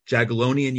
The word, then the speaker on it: Jagiellonian: Jonathan taught modern Hebrew at Krakow's, uh, forgive me if I'm mis- mispronouncing it, Jagiellonian University.